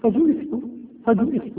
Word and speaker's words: فجئت: وقال في رواية يونس: لا تلفظ، قال بجيم مضمومة ثم همزة مكسورة. إذن كيف نقرأها؟ فجئت.